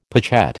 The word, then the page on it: pochette: American English